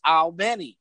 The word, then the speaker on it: Albany: And when you say Albany, if you have a wife, you should know it's Albany.